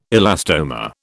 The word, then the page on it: elastomer: British English